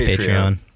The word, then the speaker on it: Patreon: It's Patreon.